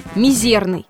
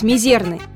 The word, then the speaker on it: мизерный: Так что классически правильно будет сказать «мизерный». Но и сказать «мизерный» все же не будет большой ошибкой.